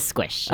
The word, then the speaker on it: SQSH: Do you pronounce it SQSH? Because I always say SQSH when I see it. – Yeah, it is SQSH.